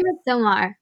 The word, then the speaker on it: Midsommar: I say Midsommar, but yeah, I really want her to watch it.